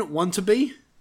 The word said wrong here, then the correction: Watanabe: Wantabe what? – I don't know. Watanabe. – I think it's Watanabe. – I don't know how to pronounce it. All right. Wasabi. As Dr. Ishiro. – Yeah, it's Watanabe.